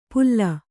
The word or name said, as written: ಪುಲ್ಲ